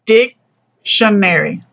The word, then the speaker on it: dictionary: Es por eso, tú necesitas hablar la palabra dictionary, como esta. Es más fuerza en la primera parte de la palabra.